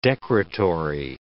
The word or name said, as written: decretory